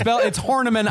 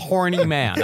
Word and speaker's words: Horniman: Annie, it's Horniman. I know it's Horniman, but it's spelled Horny Man.